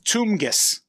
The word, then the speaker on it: TMGS: So you compared it to the AM/PM commercial mascot, which is TMGS. – Tumgus is how you pronounce Tumgus.